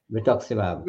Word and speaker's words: rituximab: Melis says, I don't know how to pronounce this drug here. Is it rituximab? – Ritux, yes.